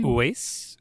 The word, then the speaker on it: Uwais: Uwais. Uwais.